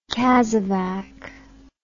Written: casevac

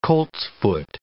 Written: coltsfoot

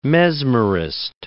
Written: mesmerist

